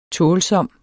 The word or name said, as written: tålsom